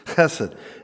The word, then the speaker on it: chesed: You got to say that. It's not just I'm not going to say it like that. – Yeah, in the Hebrew, chesed.